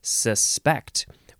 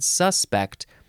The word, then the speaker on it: suspect: I think it is suspect, where you emphasize the second syllable because that's more of a verb. But if you emphasize the first syllable and you said suspect, that would be more of a noun.